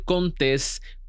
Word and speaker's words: Contés: Mi nombre es Miguel Contés con acento en la E.